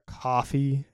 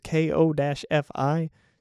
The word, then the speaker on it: Ko-fi: I started up a Ko-fi, or a coffee, as people always say, but it's spelled K-O-F-I. So it just feels like it should be, oh, you know what? It should be Ko-fi, like lo-fi, but Ko-fi.